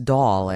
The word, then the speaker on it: Dahl: I never know how to say his name. – Dahl. – It's Dahl, actually.